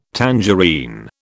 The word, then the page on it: tangerine: British English